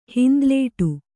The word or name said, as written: ಹಿಂದಲೇಟು